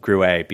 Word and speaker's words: Gruet: It's called, Americans would say Gruet, but it's actually pronounced Gruet because a family from Champagne studied soil types and climates all throughout the United States before purchasing a very large tract of land in between Albuquerque and Santa Fe.